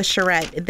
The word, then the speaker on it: charrette: Even the Cultural Council is doing an upcoming, how do we pronounce that, a charrette? – It is the charrette.